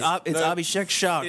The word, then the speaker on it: Abhishek Shah: It's Abhishek Shah, correct? – It's Abhishek Shah.